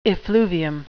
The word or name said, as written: effluvium